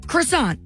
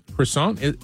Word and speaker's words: croissant: Croissant? Croissant. – Croissant. Yeah. – The T is silent. – Right. Not croissant. Tuh.